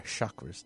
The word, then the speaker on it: chakra: We're covering our chakras today. Our chakras is pronounced correctly. Is that chakra. – Right? – Yes.